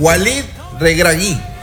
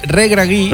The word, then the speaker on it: Walid Regragui: Walid Regragui. – Regragui.